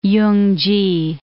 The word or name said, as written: Yungki